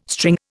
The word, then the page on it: string: Indian